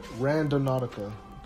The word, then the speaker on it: Randonautica: Or um Randonautica. That's how you pronounce it. It's kind of like random and nautica put together, mm-hmm.